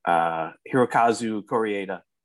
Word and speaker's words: Hirokazu Koreeda: Directed by, see if I can pronounce this name, Hirokazu Koreeda. – Perfect. You nailed it.